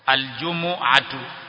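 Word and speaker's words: الجُمُعَة: Tamko la kwanza utamkwa hivi al-jumu'atu, al-jumu'atu, yani jimu inakuwa na dhamma na mimu pia inakuwa na dhamma, kisha ain ndinakuwa na fatha,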